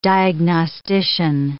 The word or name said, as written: diagnostician